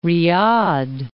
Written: Riyadh

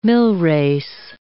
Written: millrace

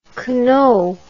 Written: Queneau

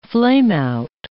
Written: flameout

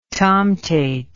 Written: tomtate

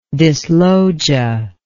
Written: dyslogia

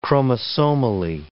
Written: chromosomally

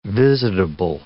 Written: visitable

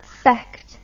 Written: fechter

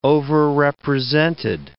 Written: overrepresented

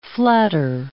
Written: flatter

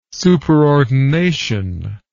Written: superordination

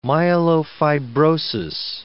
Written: myelofibrosis

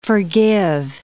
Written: forgive

